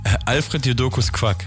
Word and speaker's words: Alfred Jodokus Quack: Ähm, sehr viele werden natürlich seine Cartoon-Figur wie, wie, spricht man es aus? Jadarius Quack oder irgendwie so? Nee, äh, Alfred Jodokus Quack. So rum.